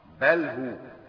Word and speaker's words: بله: وهيا بمعنى أسرع، ورويدا بمعنى أمهل، رويدا فلان أي أمهل، وبله بمعنى أترك أيضا، بله نعم بله نطقها صحيح بله.